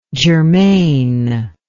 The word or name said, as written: germaneness